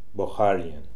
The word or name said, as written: Bokharian